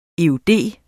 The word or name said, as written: eud